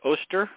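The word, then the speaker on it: Oester: Ron Oster, or how do you pronounce it? Oster? – Oster. Ron Oster.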